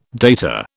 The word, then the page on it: data: British English